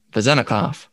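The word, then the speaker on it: Vezenkov: Now, are you sure you pronounced that right? – Nope. Not at all. – Alec, it's A L E K S A N D A R, Alexander Vezenikov. And then, uh, Rod- man, these are some tough names.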